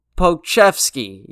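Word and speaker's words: Pokusevski: Yeah, it's Alex Alexi Poke Pochevsky. Yeah, that's how you say it. Pochevsky.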